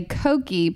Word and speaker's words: koki: And in Hungary, you say koki, pronounced like cookie, if you're referring to a small penis.